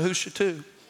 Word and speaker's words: Hoo Shoo Too: Y'all ever heard of Houshatou? Y'all know that's a road in Baton. There's a road. I love the name. Houshatou. I mean, it's spelt just like it sounds. H-O-O, only in Louisiana. H-O-O-S-H-O-O-T-O-O.